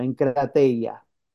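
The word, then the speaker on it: enkrateia (ἐγκράτεια): Dominio propio en el griego es enkrateia con K, así como se pronuncia, enkrateia.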